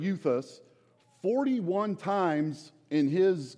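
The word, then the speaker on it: euthus (εὐθύς): It's pronounced euthus, and that means immediately.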